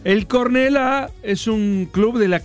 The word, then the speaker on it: Cornellà: Cornellà. – ¿Qué? – Con acento en la Cornellà.